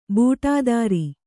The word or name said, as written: ಬುಟೇದಾರಿ